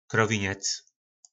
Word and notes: krovinec: po našimu

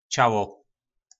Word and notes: Calo: po nasimu

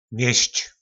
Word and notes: Mjysc: po nasimu

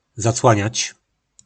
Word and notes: Zaclanac: po našimu

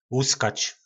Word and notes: luskac: po našimu

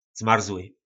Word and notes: zmarzly: po nasimu

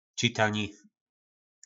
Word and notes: citani: po nasimu

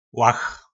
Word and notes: lach: po našimu

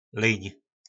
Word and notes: lyn: po našimu

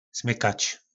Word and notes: smykac: po nasimu

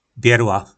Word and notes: Berla: po nasimu

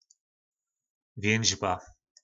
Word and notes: vjynzba: po našimu

